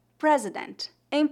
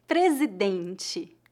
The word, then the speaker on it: presidente: Note que adicionamos a letra E ao final. Presidente. Observe que a letra R no encontro consonantal PR é pronunciada com a vibração da ponta da língua no céu da boca. Pre, presidente. Novamente, a sílaba tônica é a penúltima e as letras TE no final são pronunciadas como TI, presidente.